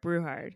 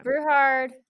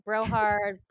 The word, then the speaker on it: Brewhard: He's a Brewhard or Brauhard? Brewhard. It's okay. Brewhard in German. Um, Americans say everything wrong, so I get Brohard, Brewchard, Brocard. It's yeah.